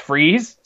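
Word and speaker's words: Fries: His name is pronounced Freeze, so it works.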